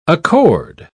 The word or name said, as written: accord